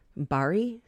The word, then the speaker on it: Bari: The bottle it was carried in was labeled with Saint Nicholas of Barley or Bar- of Barley. Why can't I say his name? Bari, Saint Nicholas of Bari.